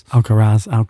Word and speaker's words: Alcaraz: Obviously, Carlos Alcaraz, I've said that right, isn't it, the chap? – No. – How do you say it? – Alcaraz. – Tomato, tomato.